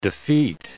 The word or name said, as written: defeat